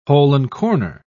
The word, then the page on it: hole-and-corner: British English